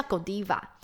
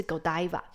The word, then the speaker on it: Godiva: 好，那接下来另外一个比利时还有一个知名的品牌叫做 Godiva，对，有人叫 Godiva，美国发音啦，那比利时当地的发音是 Godiva。